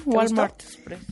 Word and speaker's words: Walmart Express: Oigan, son las 11:53 de la mañana, ya saben que somos fans en este programa de Walmart Express. ¿Lo dije bien? – Muy bien, Walmart Express.